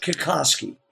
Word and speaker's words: Kikoski: Kikoski.